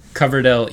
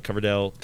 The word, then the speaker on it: Coverdell: And then there's the, how do you pronounce the other one? – Coverdell ESA. – ESA. Coverdell? – Yes.